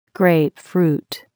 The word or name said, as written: grapefruit